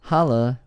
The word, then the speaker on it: hola: Hola. That's how they say hello. – Unbelievable. – What? The H is silent? – Yes.